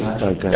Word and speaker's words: dargash: Or a dargash. How do they pronounce it here? What's the dargash? – Dargash. Okay. Dargash.